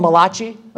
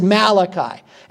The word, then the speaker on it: Malachi: Now, when I have one of my friends, my pastor friends, they call him Malachi, okay? And I got to be careful because every now and then that'll creep into my language. But the proper pronunciation is Malachi.